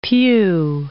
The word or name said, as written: piu